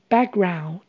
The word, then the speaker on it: background: background 这个字的发音请留意，a 是发 a 的蝴蝶音，ck 的 k 和 g 的 g 发音部位接近，所以母语人士常常把 ck 发音省略掉。 ou 是发 ow 的声音，所以就会念成 background，background。 Background, just touch the button，就按一下按钮就好喽。